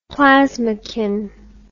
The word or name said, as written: plasmochin